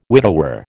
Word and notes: widower: American English

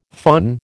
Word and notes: föhn: American English

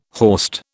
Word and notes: horst: British English

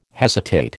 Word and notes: hesitate: American English

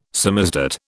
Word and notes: samizdat: British English

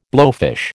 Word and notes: blowfish: American English

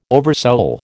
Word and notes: oversoul: American English

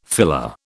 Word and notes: filler: British English

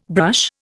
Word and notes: brush: Indian

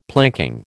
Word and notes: planking: American English

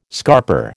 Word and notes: scarper: American English

